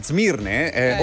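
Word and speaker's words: Smirne: Turchia, a Zmirne, ora chiamata Izmir. Un tempo si chiamava Zmirne con la Z, si scrive con la S, adesso Izmir.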